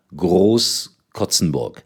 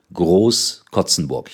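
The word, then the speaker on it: Groß-Krotzenburg: Der Verein heißt richtig Germania Groß-Kotzenburg. Nach 0:5, 7:5 gewonnen in der Landesliga in Hessen. Also Germania Groß-Kotzenburg. Ich habe das gestern irgendwie falsch ausgesprochen.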